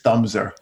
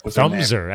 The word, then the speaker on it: Thumbser: Wait, how'd you say her name? – Mrs. Thumbser. – Thumbser, as in like the, your thumb? Okay.